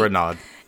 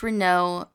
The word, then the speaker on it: Renaud: Renaud. It's still a debate. R-E-N-A-U-D. – But so Renaud. – It's okay, but it's pronounced Renaud correctly. I go by Renaud because it's easier.